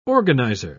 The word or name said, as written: organizer